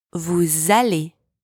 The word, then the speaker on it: vous allez: It's also vous allez, not vous allez. Same here, you link the two words together with the Z sound.